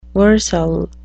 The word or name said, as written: versal